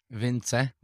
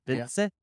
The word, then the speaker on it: Vincze: Pretty close. Vincze. – Like, yeah. You kind of have to, like, let it go at the end. Vincze.